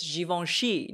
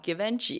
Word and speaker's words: Givenchy: It's Givenchy, not Givenchy.